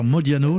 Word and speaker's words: Modiano: Vous nous faites partager votre album Sings the Songs of the Nobel Prize Winning Author Modiano.